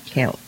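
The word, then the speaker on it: Celt: Và dân tộc này gọi là Kẹo. Đó, cái chữ C nhưng mà nó đọc là Kẹo.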